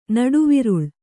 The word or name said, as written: ನಡುವಿರುಳು